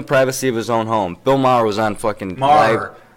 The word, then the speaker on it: Maher: Bill Maher. – I said it right the first time? – Maher.